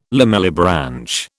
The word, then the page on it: lamellibranch: British English